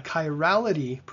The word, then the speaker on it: chirality: They had a chirality problem. Did I say that right, first of all? – Chirality, that's the handedness from the Greek word for hand.